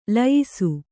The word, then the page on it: ಲಯಿಸು: Kannada